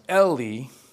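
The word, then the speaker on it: Heli: So you see the H, but it's actually pronounced Eli.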